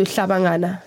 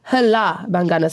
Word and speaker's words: Hlabangana: They first like mess so my surname is the you spell it HLA, that's the so you put, that's like basically one sound. – Okay. – Right? The kids, well, because they don't really know how like, you know, the language works or whatever, but they'll go, Hla Bangana. So they'll pronounce each letter separately, but then when I say to them, no, it's Hla Bangana.